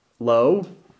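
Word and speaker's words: low (לא): Now, both in Hebrew, not us is low, and to Him is also low. Spelled differently, but same pronunciation.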